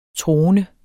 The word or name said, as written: troende